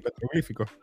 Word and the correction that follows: petroglifos: Sí. – Petroglifos. petroglifos, perdón. Esos petroglifos estaban haciendo la historia de cómo comenzó eh, el ciclo de los zombies.